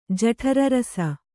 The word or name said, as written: ಜಠರರಸ